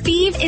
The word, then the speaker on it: Fives: Feed is spelled F-I-V-E-S, like the number, but pronounced Feed.